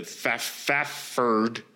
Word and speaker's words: Fafhrd: Basically, they're like Conan, like the main guy, the Fafhrd, because it's spelled F-A-F-H-R-D.